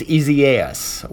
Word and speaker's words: Isaias: Uh, if you look at the name, it looks like the biblical name Isaiah, but it is not pronounced that way. Uh, I'm guessing it is the Spanish version of the name Isaiah, but it's and it is pronounced Isaias, which is uh, a little difficult to say.